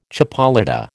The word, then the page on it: chipolata: American English